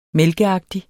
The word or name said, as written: mælkeagtig